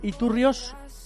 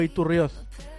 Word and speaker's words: Iturrioz: ¿es Nuria Iturrioz o Iturrioz? Hola, Nuria, muy buenas. – Buenas. – ¿Cómo es? ¿Con Z, con S? ¿Con Z pronunciado con S?